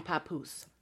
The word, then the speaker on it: Papoose: Oh, Remy Ma and Papoose. Is that how you say his name? – Mm-hmm. – Papoose? – Mm-hmm.